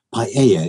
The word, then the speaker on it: paella: Paella is my favorite dish in the world ever.